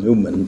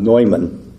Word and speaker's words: Neumann: His name was John Neumann, N-E-U-M-A-N. Some pronounce it John Newman, but in the German, it was John Neumann.